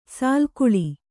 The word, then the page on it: ಸಾಲುಗೊಳ್ಳು: sālugoḷḷu